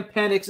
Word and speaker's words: Penix: We have Sam Penix.